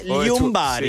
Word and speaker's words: Ljungberg: Sapete che non si pronuncia Erg, ma si pronuncia Ljungberg. In svedese, Ljungberg.